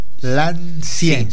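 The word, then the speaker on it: L'Ancienne: En Instagram, es Lancien Boutique. – Lancien. – Sí, se escribe LANCENNE.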